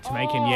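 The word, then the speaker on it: Temiekan: So it's T-E-M-I-E-K-A-N. – Oh, Tamekin, yeah.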